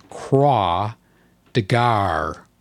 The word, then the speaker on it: Croix de Guerre: Croix de Guerre is pronounced Croix de Gare or something close to that.